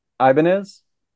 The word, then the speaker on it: Ibanez: Ibanez is how you pronounce it in American English. I don't know if that's how he pronounces it, but yeah, Ibanez is actually a very famous name in the United States because it's a guitar brand.